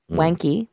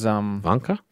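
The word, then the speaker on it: Wanke: And then Wanka is... – Wanka? – Yeah, that's how it's actually meant to be pronounced. – Ernst Wanka. – If it was German. – Ernst Wanka. – But we say Ernst Wanky.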